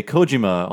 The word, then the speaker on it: Kojima: But when I say, like, the creator of Metal Gear, when I say Kojima on a podcast, people are like, why are you saying it like that? It's like, that's how you say his name.